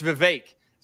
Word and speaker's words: Vivek: It's Vivek. So apparently he does it like cake, right? Vivek cake. Yep. So that's how you pronounce his name.